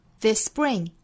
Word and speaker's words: spring: This spring, this spring, 还要注意 spring 这个词里面的这个 p 的发音是不送气的。